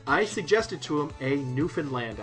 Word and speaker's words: Newfoundland: How it's spelled, but anyone who knows anything about Newfoundland culture knows that it's pronounced Newfoundland.